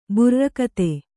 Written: ಬುರ್ರಕಥೆ

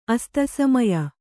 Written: ಅಸ್ತಸಮಯ

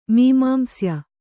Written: ಮೀಮಾಂಸ್ಯ

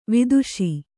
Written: ವಧಿಸು

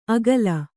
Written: ಅಗಲ

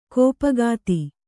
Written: ಕೋಪಗಾತಿ

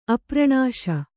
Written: ಅಪ್ರಣಾಶ

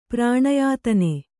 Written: ಪ್ರಾಣಯಾತನೆ

